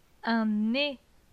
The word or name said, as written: Un nez